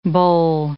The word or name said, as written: boll